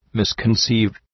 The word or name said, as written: misconceive